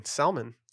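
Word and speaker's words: Salman: I don't think it's pronounced like that. – It's S-A-L-M-A-N, Salmon. – Okay, again. – Like Salmon Rushdie.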